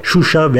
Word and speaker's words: Şuşa: Karabağ'daki yerleşimlerin isimlerini Birleşmiş Milletler tarafından da kabul edildiği şekliyle Şuşa ve Han kendi şeklinde telaffuz edin dedi Bayramov.